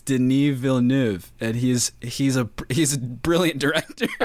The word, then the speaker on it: Denis Villeneuve: Can you pronounce his last name? – It's Denis Villeneuve. – Yeah, which is not even close to how it's spelled.